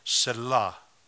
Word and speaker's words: Selah: En hebreo se lee Selah. Es una palabra mal pronunciada por los americanos.